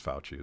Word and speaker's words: Fauci: And I, I think that, um, I mean, Rand is, is tweeting that, that he's, he's, um, he's filed charges against Fauci.